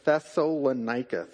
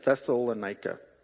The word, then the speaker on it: Thessalonica: Thessalonica. Thessalonica. I butchered that the first time, right? Thessalonica.